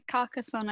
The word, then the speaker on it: Carcassonne: And he got me into playing, like, Carcassonne. Oh, my gosh, I'm going to say it wrong, aren't I? – Yeah, Carcassonne, I think, is right.